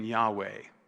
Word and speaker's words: Yahweh: About 30, 40 years ago, they really popularized saying Yahweh, which is a two consonants, probably the more proper pronunciation. But in the end, we don't know what the original vowel pointings were. They were never written down. So Yahweh is the best guess we have.